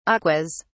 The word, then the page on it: aquas: British English